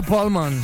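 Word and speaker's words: Pall Mall: Se pronuncia Paulman. No tiene una N al final, pero es Paulman.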